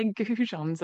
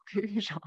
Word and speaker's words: goujons: And it turns out that they're goujons and not coujons.